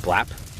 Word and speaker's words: Blap: Blap.